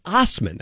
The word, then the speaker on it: Assman: David Osman, that's how he pronounces it. – But spells it differently, with an A, not an O.